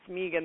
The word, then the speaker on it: Megan: And my name actually does rhyme with vegan. It's Megan the Vegan.